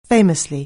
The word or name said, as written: famously